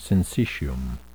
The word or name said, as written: syncytium